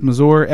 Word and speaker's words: Masur: And I will say, his dad is played by Richard, I believe it's pronounced Masur, M-A-S-U-R, who is really slumming it here.